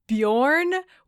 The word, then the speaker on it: Beorn: So I listened to the audiobook to get the pronunciation, because that's spelled Beorn. When I was reading it, I was saying it in my head like Bjorn. – It's a very Scandinavian vibe. – And the audiobook pronounces it Bjorn, which I think is the name of the baby carrier brand.